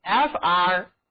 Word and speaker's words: frank: rank 有一个形式看上去特别像的单词，那就是 frank， frank， frank 坦白的、直率的。自然了，这里字母 n 也要读作后鼻音，嗯，好了， frank 它还是人的名字，那就是弗兰克。